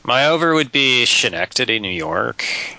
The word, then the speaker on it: Schenectady: I'm also the guy who knows how to pronounce the name of the movie, which is Schenectady.